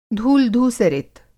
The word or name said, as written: धूल-धूसरित